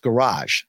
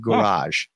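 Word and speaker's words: Gouraige: Our good buddy in the chat, I think it's Rick Rarick there, pronounced Garage. So Richard Garage.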